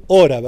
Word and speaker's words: Hora: ¿Cómo se pronuncia exactamente el apellido? Hora. – Bueno, depende, ¿viste? Acá se pronuncia así, en otros lugares se pronuncia distinto. – Perfecto. El Howrah no estaba tan mal entonces. – No, es así. En Inglaterra es así.